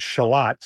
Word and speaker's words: Charlotte: Well, you know, this was a revealing story for me because I grew up in a Rochester in which the neighborhood Shalott, spelled like Charlotte, but it's pronounced Shalott.